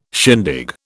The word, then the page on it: shindig: British English